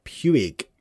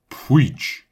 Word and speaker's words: Puig: There's no I in it at all when that guy says, Puig. The commentator that does, it's David Puig.